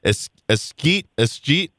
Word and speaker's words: escheat: That was a word I always struggled with in law school was, they talked about it in trust and estates class, escheat, if something doesn't get claimed through probate, the money escheats to the state.